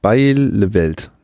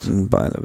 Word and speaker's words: Bijleveld: Da hat nämlich die niederländische Verteidigungsministerin Bijleveld, ne, heißt das doch, glaube ich. Ne? Ich bin leider des Holländischen nur sehr eingeschränkt mächtig. – Sie hat Bielefeld. Bielefeld. Würde ich das aussprechen. – Okay, gut. Ich habe da. – Oder so Bielefeld sogar. – Oder Feld, genau.